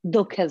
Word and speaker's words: dukkha: In English, it would be, if you write it down, it'll be pronounced dukkha, but dukkha is what we say, right?